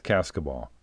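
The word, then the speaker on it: Cascable: And by the way, it's spelled C-A-S-C-A-B-L-E. C-A-S-C-A-B-L-E, but it's pronounced Cascaball.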